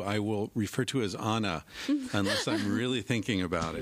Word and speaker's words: Ana: See, there's no Anna in Spanish. It's Anna, especially with one N.